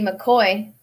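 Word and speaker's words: McCaughey: I was happy to see my friend Betsy McCoy.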